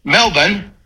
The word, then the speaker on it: Melbourne: Whereas I understand Australians pronounce it Melbourne. – Correct. – That's how you say it, right? – Hey, Melbourne, excellent job.